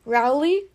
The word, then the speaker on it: Rowley: Rowley? – Yeah.